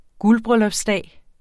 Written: guldbryllupsdag

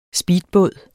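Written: SPEEDBÅDEN

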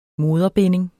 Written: moderbinding

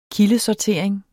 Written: kildesortering

